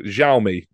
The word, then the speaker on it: Xiaomi: So for this match only, Harlequins were partnered with a new tech company, sort of phones and watches, called Xiaomi, I think was the correct pronunciation in the end.